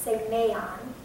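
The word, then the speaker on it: semeion (σημεῖον): And it's pronounced semeion. Like, you need to know that.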